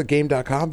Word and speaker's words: Game.com: This thing, I'm pretty sure it's pronounced Gamecom, because I feel like that's how they said it in the commercials, which is silly, because it's not written that way.